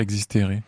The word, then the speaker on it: Existere: Alors expliquez-nous ce titre Existere, si je prononce bien. – Oui, tout à fait,